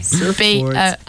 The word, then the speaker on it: Surfbort: S-U-R-F B-O-R-T. – Like T? – Spelled like surfboard regularly, yeah? – Bought. – Bought. – It's a T. – Surfboard. – It's with a T? – Yeah. – No A. – Surfboard.